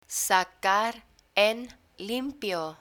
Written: sacar en limpio

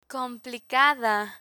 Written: complicada

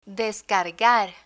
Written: descargar

